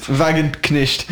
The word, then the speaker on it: Wagenknecht: Wagenknecht, je sais pas si je prononce bien. – Wagenknecht. – Ouais. Très bien.